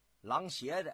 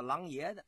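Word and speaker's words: 琅琊: 咱们在这儿、啊、再给您说一下，也有啊管他叫、啊、狼邪的，也有管他叫狼爷的，爷邪啊怎么都行，也是一个多音字，左边啊一个牙字，就是牙齿的牙，右边单耳旁。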